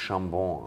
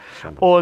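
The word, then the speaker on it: Chabon: Übrigens, spricht sich Chambon aus. – Oder Chambon und Chambon, glaube ich. Chambon.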